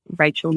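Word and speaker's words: Rachael: If you want to talk through anything or have a chat or whatever, mine is Rachel Knowles. The Rachel is spelled a little bit funny. It's R-A-C-H-A-E-L and then Knowles like Beyonce underscore.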